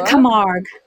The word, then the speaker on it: Camargue: So in the region is the, I'm going to butcher the French, the Camargue. – The Camargue. Camargue. – The Camargue. Camargue. – It's just like it looks.